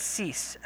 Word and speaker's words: Seese: It's Cease, S-E-E-S-E.